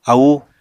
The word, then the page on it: aúu: apatani